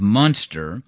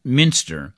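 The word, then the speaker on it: Münster: Now, that town is usually better known by its anglicized name of Munster, but it's my understanding that the correct pronunciation is Minster.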